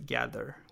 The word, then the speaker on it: Gaddr: It's spelled G-A-D-D-R, right? So it's not Gather as the English spelling, but it's Gather with two D's.